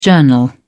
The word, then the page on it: journal: British English